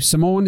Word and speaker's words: Simone: In, I think it was 2018, you had your TED Talk with your fiancé, Simone. Is it Simone? – Yeah. You pronounce it Simon, like sea monster.